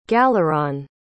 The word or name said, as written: galeron